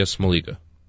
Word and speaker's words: Smoliga: What's her name? Olivia Smoliga. – Smoglia. Smoliga? – Mm-hmm.